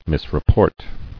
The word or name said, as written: Misreport